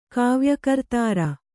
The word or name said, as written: ಕಾವ್ಯಕರ್ತೃ